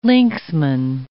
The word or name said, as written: linksman